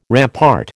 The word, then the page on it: rampart: American English